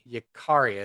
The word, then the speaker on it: Yikaria: Y-I-K-A-R-I-A. Yakaria is how I would pronounce it.